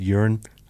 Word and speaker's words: Euron: You notice I say his name now is just Euron.